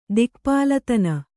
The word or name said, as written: ದಿಕ್ಪಾಲತನ